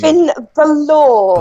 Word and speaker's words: Finn Balor: What's the pronunciation of Finn Baller? Uh, can you Finn Balor? – Balor. Okay, sorry about that.